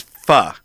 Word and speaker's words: pho: It's pho.